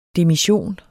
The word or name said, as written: DEMISSIONER